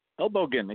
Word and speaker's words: Elbogen: Elbow again, exactly right.